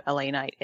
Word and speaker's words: LA Knight: You said it wrong. – It's L.A. Knight.